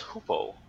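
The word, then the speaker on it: Hoopoe: It's Hoopo. – Hoopo? – Hoopo. – River Hoopo.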